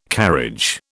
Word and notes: carriage: British English

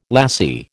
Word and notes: lassie: American English

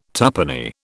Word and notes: tuppenny: British English

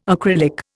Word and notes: acrylic: Indian

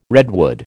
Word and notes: redwood: American English

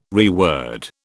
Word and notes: reword: British English

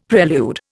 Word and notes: prelude: Indian

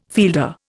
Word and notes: fielder: Indian

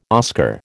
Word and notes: Oscar: American English

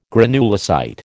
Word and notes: granulocyte: American English

